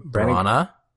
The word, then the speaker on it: Branagh: How do you pronounce his name? – Branagh. – Mm-hmm. – Kind of like piranha. – Piranha. – Rhymes with piranha.